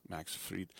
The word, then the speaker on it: Max Fried: You might wonder why it's not fried, because he pronounces it the German way. E after I is E. Max Fried.